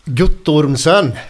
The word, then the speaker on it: Guttormsen: Hur uttalar man, uttalar man efternamnet? – Guttormsen.